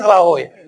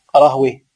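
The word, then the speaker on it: راهويه: راهوي، لا راهوي هذا عند أهل اللغة، راهوي سيبوي نفطوي، أما عند أهل الحديث سماعا راهوية سيبوية نفطوية.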